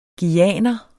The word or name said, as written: guyaner